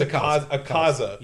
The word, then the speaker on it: Akaza: It is Akaza. I've been Asaka. Sorry about that. – It's Akaza.